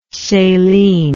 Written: celine

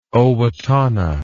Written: Owatonna

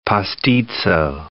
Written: pastitso

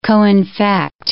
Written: coinfect

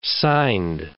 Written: signed

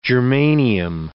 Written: germanium

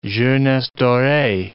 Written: jeunesse doree

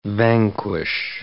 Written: vanquish

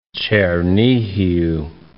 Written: Chernihiv

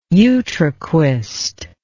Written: utraquist

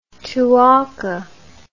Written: Tuaca

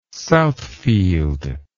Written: Southfield

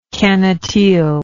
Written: cannetille

